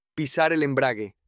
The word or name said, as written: pisar el embrague